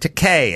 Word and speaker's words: Takei: I used to say George Takei, and Jimmy said Takei as in gay, and I've never screwed it up again.